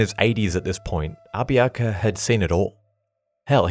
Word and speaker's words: Abiaka: The other man who I've been fascinated about since I learnt about him was Abiaka. It's often spelt many different ways, but I'll be pronouncing it as A-B-I-A-K-A, Abiaka, also known by his American name, Sam Jones.